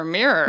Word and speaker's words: Mirer: It's M-I-R-E-R. – Sure, Mirer. – Mirer. That's how I'm going to pronounce it. – Okay. – Okay. Anyway, her lawyer, Michael Myers.